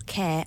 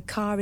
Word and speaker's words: Kerr: Because I know people with the surname K-E-R-R, and some of them are pronounced Kerr, and some are Care, and also Carr is another one.